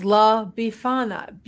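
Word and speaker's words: La Befana: It's La Bifana. B-E-F-A-N-A. – La Bifana.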